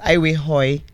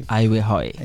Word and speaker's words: Aiwekhoe: And Aiwehoi is my surname, my husband, my married name. – Aiwehoi. – Yes. A-I-W-E-K-H-O-E. So the K is silent. – Exactly. Aiwehoi.